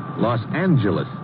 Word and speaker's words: Los Angeles: Los Angeles. – Correction. Los Angeles. – Oh? – Yeah, soft G.